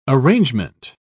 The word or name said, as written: arrangement